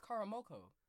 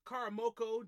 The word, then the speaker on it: Karamoko: 15-year-old soccer wonder boy, Karamoku Dembele. – Why in the hell did you? Why'd you say "koo"? It's Karamoko. – Karamoko Dembele.